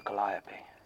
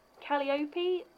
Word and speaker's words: Calliope: So, do we know if it's pronounced Calliope or Calliope? – I have also heard it said as Calliope. – Seriously? By who? – Americans. – Ah. – As far as I can tell, there isn't a correct pronunciation, but they were originally named after the Greek muse Calliope, so.